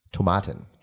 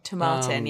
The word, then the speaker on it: Tomatin: Tomatin. – Tomatin, yeah.